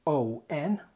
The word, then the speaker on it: on: O-W-N. إيش معنى؟ لأن دي نطقها كده own. Own. إنما التانية O-N نطقها كده on. On. تعال ننطقهم ورا بعض عشان يتضحوا. هتلاقي ان OWN ممدودة شوية والبق بيبقى مضموم فيها بعكس ON قصيرة والبق مفتوح.